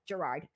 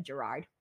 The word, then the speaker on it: Gerard: My name is pronounced Gerard again. I'm telling you, it just goes off my head. You want to know why? I think Jared, someone's name who's Jared, it's spelled similar to yours. And I think that's why I keep calling you Jared instead of Gerard.